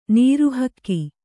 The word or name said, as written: ನೀರುಹಕ್ಕಿ